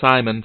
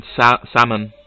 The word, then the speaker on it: salmon: No, not Simon. Salmon. Like the fish.